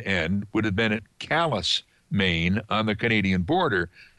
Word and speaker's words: Calais: Calais, Maine is spelled like the French city Calais, but it's pronounced Calais.